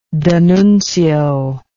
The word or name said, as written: D'Annunzio